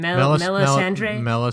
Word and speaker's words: Melisandre: Melisandre.